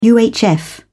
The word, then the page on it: UHF: British English